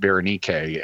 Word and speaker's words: Berenike: Berenike we bring into English as Bernice, but in Greek you pronounce it Berenike, and it was a common name.